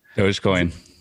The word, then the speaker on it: Dogecoin: And is it Dogecoin? What's the right way to say Doge? – Dogecoin. – Dogecoin? Yeah. Okay.